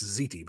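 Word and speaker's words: ziti: I think it's pronounced Zeti, but anyway.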